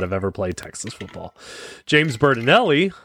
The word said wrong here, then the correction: Berardinelli: or Berardinelli, I always say this guy's name wrong, Berardinelli,